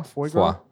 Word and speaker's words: foie gras: Foie gras? – The latter.